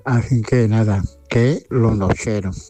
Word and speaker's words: Nocheros: Entonces, me gustaría dedicarle esa canción de Mamá de los Nocheros. Esta vez lo he dicho bien. No he dicho ni los mecheros ni los lecheros, sino los nocheros.